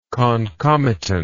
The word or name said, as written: concomitantly